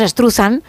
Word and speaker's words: Struzan: Bueno, pues Drew Struzan o Struzan o como cada uno lo quiera pronunciar, nosotros decimos Struzan.